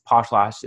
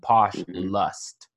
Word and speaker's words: Poshlost: And so when Nabokov tried to make Poshlost palatable to Americans, he changed the lost, which is L-O-S-T. It's Poshlost. It's spelled P-O-S-H-L-O-S-T to posh lust. L-U-S, because a part of the vulgarity of being a poshly person is that you lust after people.